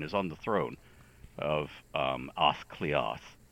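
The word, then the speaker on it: Áth Cliath: And before everybody who speaks Gaelic jumps down my throat about the pronunciation, that, as far as we know, is the most accurate pronunciation for the time. Gaelic has had a great vowel shift like everything else. And so when somebody who is a modern speaker says, oh, that should be Ath Cliath or whatever, you go, yeah, well, okay, in 1735 it would have been Ath Cliath.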